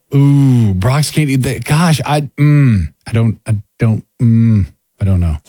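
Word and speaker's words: Brach's: Because I discovered that Brock's, B-R-A-C-H-S, you know, the candy maker? – Oh, yeah, definitely, It's Brock's, right? – It's Brock's. – That's how you say it. – That's the way I say it.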